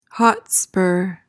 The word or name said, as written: hotspur